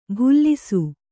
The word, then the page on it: ಭುಲ್ಲಿಸು: bhullisu